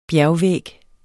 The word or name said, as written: bjergvæg